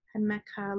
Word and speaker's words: jamaica: That's called it's spelled like Jamaica, but it's Jamaica. Jamaica leaves.